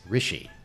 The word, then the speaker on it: Rische: You actually are wrong, because I finally learned how to pronounce her name correctly. It's Rishi.